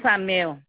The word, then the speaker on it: Samer: Madame Samer. – Ça s'écrit comment? – S-A-M-E-R.